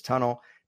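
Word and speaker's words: Tunnell: Some people call him Emlyn Tunnel. I believe the proper pronunciation is Tunnel.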